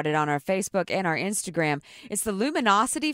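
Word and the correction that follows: LuminoCity: Festival. Lumino City. I've been saying it wrong. Lumino City Festival.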